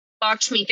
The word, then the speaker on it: Lakshmi: Lakshmi, thank you. I could not get that out of my mouth. – I don't even know if I'm pronouncing that correctly, but no, you are.